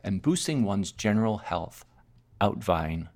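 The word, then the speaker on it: uitwaaien: Outvine. It's spelled U-I-T-W-A-A-I-E-N. U-I-T-W-A-A-I-E-N. It's the Dutch practice, it's pronounced outvine,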